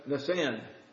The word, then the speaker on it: Nisan: The first month is the month I would say Nisan, but I spent some time this afternoon on YouTube, and how do you say? And they ask Nisan. That's how they said it.